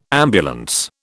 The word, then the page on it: ambulance: British English